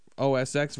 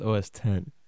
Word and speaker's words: OS X: And you know, we did want to talk about Firefox and the extensions and stuff, but real quick, though, the new Mac OS X version, codenamed Tiger. – Did you say OS X? – 10.4? – It's spelled that way, but it's pronounced OS 10.